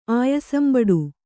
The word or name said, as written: ಆಯಾಸಂಬಡು